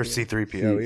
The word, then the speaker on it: C-3PO: He's as cool as R2-D2, but not as annoying as CP3PO. – Or C-3PO, even. – C-3PO. Did I say it wrong? – Yeah. You did.